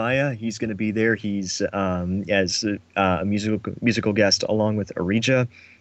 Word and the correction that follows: Origa: Isn't it pronounced Origa? – Yeah, it's Origa.